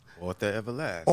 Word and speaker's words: Arthur Everlazt: Arthur Everlazt.